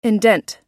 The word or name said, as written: indent